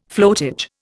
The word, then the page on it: floatage: Indian